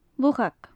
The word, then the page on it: luchag: gaelic